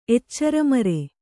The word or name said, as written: ಎಚ್ಚರಮರೆ